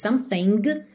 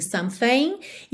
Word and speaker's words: something: A gente não diz something, mas sim something.